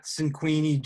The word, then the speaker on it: Cinquini: Yeah, so you can find me on Twitter at Cinquini Jesse,